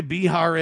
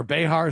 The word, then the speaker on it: Behar: All right, you know who Joy Behar is? Or Behar, is that how you say it? B-E-H-A-R, Behar.